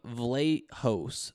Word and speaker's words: Vlachos: In his place will be William Vlachos. That's how you say it, Vlachos.